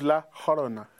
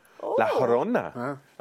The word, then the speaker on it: La Llorona: Because it's a double L. It actually doesn't get the L. – It's pronounced Yorgos Lanthimos. – The Curse of Yorgos Lanthimos. No, it's The Curse of La Llorona.